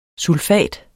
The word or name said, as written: SULFAT